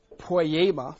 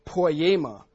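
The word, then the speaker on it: poiema (ποίημα): G4161 in the Greek, it's pronounced poiema, thank you, poiema.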